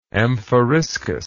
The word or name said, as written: amphoriskos